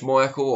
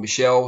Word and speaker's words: Michel: Serge Michael, or Michel, however you choose to pronounce it.